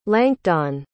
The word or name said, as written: lancdon